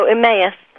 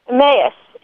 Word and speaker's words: Emmaus: How do you say it? – Oh, Emmaus. – Emmaus.